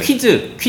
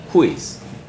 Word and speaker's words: quiz: 퀴즈, 퀴즈 이렇게 하지 마시고요. 어떻게 발음한다고요? – quiz. – 쿠를 시작해요. 쿠를 시작했어요? quiz. quiz. 네. 발음하시면 됩니다.